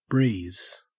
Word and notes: breeze: Australian English

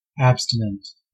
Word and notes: abstinent: American English

